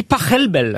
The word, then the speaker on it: Pachelbel: S'il est allemand, ça se dit Pachelbel.